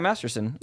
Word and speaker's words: Masterson: No, yeah, Masterson.